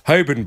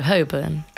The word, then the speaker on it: Holborn: I'm deliberately calling it Holborn, by the way, because somebody texted in and say the L is silent, and as a posh person, I should know that, but I call it Holborn. But no, Holborn. – Oh, it is Holborn? – Yes.